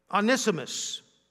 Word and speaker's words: Onesimus: Onesimus, our faithful and beloved brother in verse 9, who is one of you. I pronounced it right the first time.